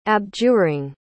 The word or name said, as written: abjuring